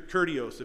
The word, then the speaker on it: kurios: Your kurios, if you want to say it the way it's written in English. K-U-R-I-O-S is how it's pronounced.